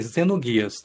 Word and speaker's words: Xenogears: Ok, é com, o, o, o, é, é, o, nome, do, a pronúncia é com Z, é Zenoguias, tá? Mas a escrita ela é com X. Ela é com X, eu e...